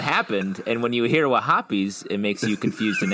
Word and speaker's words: Wahhabis: I think you said that wrong. It's Wahhabis.